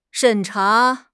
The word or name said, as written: 审查